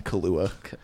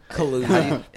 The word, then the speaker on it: Kaluuya: Kaluuya. – Kaluuya. – Pretty sure it's not pronounced like the alcohol.